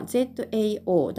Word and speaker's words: 早: でですね、 1 つ目の文字、早いという文字は読み方が ZAO 第 3 世低く沈む音。